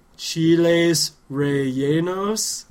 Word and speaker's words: chiles rellenos: Wait, let me make sure I'm saying it right. Chiles Rellenos? – That is correct.